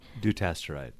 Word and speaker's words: Dutasteride: Dutasteride.